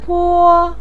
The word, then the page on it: po: 1